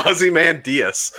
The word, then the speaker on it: Ozymandias: So by the way you pronounce that, by the way, I just think of an Australian man named Diaz, by the way. – Ozymandias? – Yeah, Ozymandias. I was like, wait, like Diaz, a man from Australia? Ozymandias? – Ozymandias.